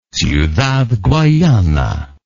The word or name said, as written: ciudad guayana